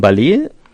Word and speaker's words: Ballet: Das ist von Ballet, heißt die Band, und reimt sich auf Array. – Ja, weil das anders ausgesprochen ist, ja französisch ausgesprochen wird.